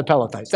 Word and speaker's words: Pelethites: That's the Perethites and the Cherethites. Oh, I said it wrong. Let me look at them again. The Cherethites and the Pelethites.